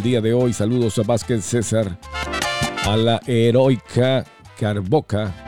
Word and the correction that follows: Heroica Caborca: o Caborcar, no sé cómo se pronuncia. Oh, es una ciudad, Heroica Caborca, sí.